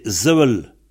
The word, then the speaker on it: Sevel: Alors, le S initial se prononce Z pour la plupart des mots. Où il y en a que quelques-uns, on pense, donc, Sevel, dans le pays pour l'être, c'est Zevel.